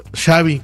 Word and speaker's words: Xavi: Y dijo, no, pues Xavi, como chocolate.